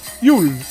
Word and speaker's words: Jul: Noël qui, en scandinave, s'écrit Jules, J-U-L, mais que l'on prononce Yule.